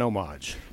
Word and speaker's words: homage: It is an homage, not a theft. – And is it homage or a homage? How do you say that? – The H is silent.